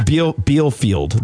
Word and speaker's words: Bielefeld: So according to the conspiracy, the city of Belfield is an illusion. It could be Bielefeld. – I think it's Bielefeld. That's how I pronounced it, I think.